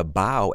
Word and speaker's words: bough: What is a bough and how do you spell it? Well, a bough is spelled B-O-U-G-H and it's pronounced bough, and it's basically a large branch from a tree.